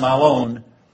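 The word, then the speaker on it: malon (μᾶλλον): That Greek word, malon, it's pronounced malon, means more.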